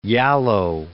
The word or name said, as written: Yalow